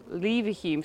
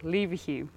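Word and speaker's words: Leverhulme: Later Lord Lever Hume. Sorry, I said his name wrong. Lever Hume.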